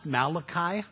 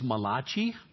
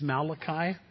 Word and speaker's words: Malachi: It's pronounced Malachi. When I first got saved, I thought it was Malachi. Until somebody told me he's not Italian. He's not Italian. He doesn't make pizzas for a living. It's not Malachi. It's Malachi is how it's pronounced.